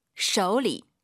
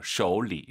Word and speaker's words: 手里: 그리고 쇼가 아니라 오유는 어우입니다. 쇼우, 쇼리 꼭 기억하시고요. 쇼, 안 돼요.